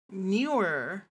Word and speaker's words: Neewer: Like, it's the word N-E-E-W-E-R. Like, Neweer? – That sounds about right to me. Sounds about right to me. – I'm gonna type it in the chat. How do you pronounce this? How do you pronounce this word? – Newer. Yeah, newer.